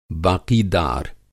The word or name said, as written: बाक़ीदार